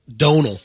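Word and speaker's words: Domhnall: It's pronounced Donal. – Donal Gleason. Yep. In his accent. Domnall is what I've always heard.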